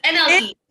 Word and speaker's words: NLE: ELE Choppa. – It's NLE.